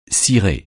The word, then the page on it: cirer: francais